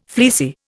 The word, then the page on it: fleecy: Indian